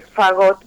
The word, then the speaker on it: fagot: Bueno, el fagot, muy bien pronunciado, es un instrumento de viento y de la familia, en todas las orquestas hay fagot.